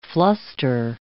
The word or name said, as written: fluster